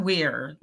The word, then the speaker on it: Weir: Dawn Weir, like Bob Weir with the Grateful Dead, okay. – The Weir fishing duck.